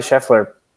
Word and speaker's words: Scheffler: And then, yeah, Scheffler, am I saying that right? Yeah. Scottie Scheffler.